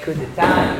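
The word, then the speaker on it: coup d'état: In 1799, he hears that there's a unrest, and Napoleon does is, he stages a coup d'etat. Did I say it right? Mm-hmm. Okay, coup d'etat.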